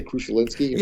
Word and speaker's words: Krzyzewski: Mike Krzyzewski. – Krzyzewski. Would I say Krzyzewski? – Yeah, well, you just read it the way it should be pronounced, because you're right.